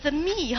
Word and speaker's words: theme: 这就是那 theme 吗？主题，这才是我要表示的精神，是不是啊？ theme 看到了哈，这几个读的时候别读成 the me 哈，人家不知道你说什么，对吧？记得哈， theme 哈，要记得。